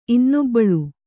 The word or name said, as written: ಇನ್ನೊಬ್ಬಳು